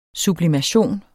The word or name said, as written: sublimation